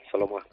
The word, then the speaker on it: Solomo: dime si lo digo bien, Solomo Piparraquín. – Bai, Solomo.